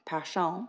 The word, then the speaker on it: Persson: Stina Persson, pronounced Passion, I found out, creates the most stunning work for clients all over the world, from Vogue Japan to Bloomingdale's and zillions in between.